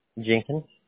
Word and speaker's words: Jenkins: Jenkins. – And how do I spell that, please? – J-E-N-K-I-N-S.